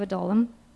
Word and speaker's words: Adullam: Adullam.